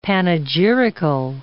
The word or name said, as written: panegyrical